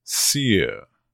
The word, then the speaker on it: sere: This is S-E-R-E, pronounced sear.